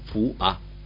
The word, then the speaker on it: foie: Foie se escribe foie y se dice fua.